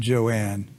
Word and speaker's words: Joan: It, it's it's spelled Joan, but it's pronounced pronounced Joanne.